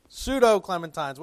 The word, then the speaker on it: Pseudo-Clementines: And then there were the Clementines, or the, how do you say it again, pseudo? Did I say it right? Yes. Pseudo-Clementines.